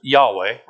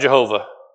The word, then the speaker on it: Yahweh: It's where we get the name of, in Hebrew, we pronounce it Yahweh, right? It's not Jehovah, because there's no J's in Hebrew.